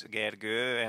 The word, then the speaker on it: Gergő: My name also has like two lines on top of the O. It's Gergő, and most of the people I talk to can't really pronounce it.